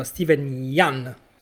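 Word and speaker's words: Steven Yeun: che è quello interpretato da Steven Yan, so che si pronuncia così, eh, che interpreta praticamente un, eh, un, ex eh, attore bambino, diciamo, come si dice, un ex...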